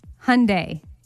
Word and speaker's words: Hyundai: And then finally, how about H-Y-U-N-D-A-I? – Hyundai. – Hyundai is right.